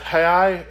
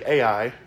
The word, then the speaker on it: Ai: and having Bethel on the west," and that Hai is actually Ai. That's what we commonly call Ai. In this particular translation, it pronounces it H-A-I.